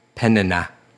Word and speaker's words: Peninnah: Peninnah. And that is exactly how that's supposed to be pronounced.